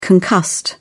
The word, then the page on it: concussed: British English